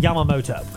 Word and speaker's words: Yamamoto: Yamamoto. – That's actually how you say it.